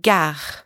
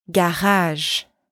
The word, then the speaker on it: gare: Now, after that, add a little friction. As if you're about to gargle, but softer. Gare. Gare. Soft, soft. No spitting, not running the R like crazy.